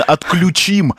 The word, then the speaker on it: отключим: Кстати, именно «отключим», но на Мордюкова на «ю» делало ударение, а это, кстати, неправильно. Не «отключим» и «включим», а «отключим-подключим». Ну, это так, уточнение.